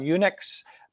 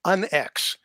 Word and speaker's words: UNX: How do you say that? Did I pronounce that correctly? – No, it's actually UNX. – UNX. – Yeah, okay. just the letters UNX, which really kind of stands for unexplained.